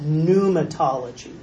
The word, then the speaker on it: pneumatology: This is called pneumatology. The P is silent.